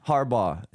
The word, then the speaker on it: Harbaugh: So Harbaugh is the name.